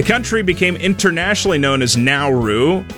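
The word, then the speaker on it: Naoero: The country's international code will change from NRU to NRO, and its people will be known as De Nauru instead of Nauruan. Commonly known abroad by the pronunciation of Nauru, the new name is spoken Nauru.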